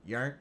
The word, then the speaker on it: Djurnt: The D is silent. So yeah. When you say Djurnt, it'd be DJ URNT, and it is a joint spliff.